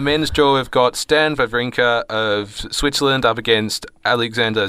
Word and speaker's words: Wawrinka: It's Wawrinka, because in German they pronounce W's as V's.